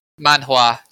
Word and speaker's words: manhua: Manhua, 2 syllables. – Manhua, right, okay. – Not manhua.